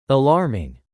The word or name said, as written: alarming